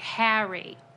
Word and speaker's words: Harry: You're saying it wrong. Because it's not supposed to sound like the hair on your head. It's like Harry.